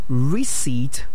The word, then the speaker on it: receipt: Receipt. Receipt. Receipt is a long E there, and what most time to say recipt. No, the P is silent, so it's receipt.